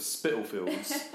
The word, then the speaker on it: Spitalfields: Well, because we're in the East End, you know, you don't have to pronounce that T. You can just call it Spitalfields. that's what we all call it.